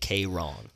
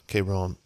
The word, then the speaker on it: Kayrxn: His name is spelled K-A-Y-R-X-N. – Yeah. – Say it like K-Ron. – K-Ron.